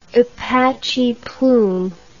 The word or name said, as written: apache plume